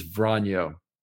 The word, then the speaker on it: vranyo: And the word is Vranyo. Okay. And it's pronounced that way anyway.